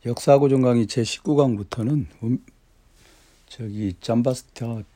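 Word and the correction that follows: Giambattista: 비코, 잠바스타, 잠바티스타, 잠바티스타. 이 잠바티스타를 발음하기가 좀 어렵죠?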